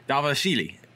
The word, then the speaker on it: Dvalishvili: John Dodson had an incredibly tough strength of schedule too, and he got cut from the UFC after losing to Dvalishvili.